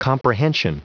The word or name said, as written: comprehension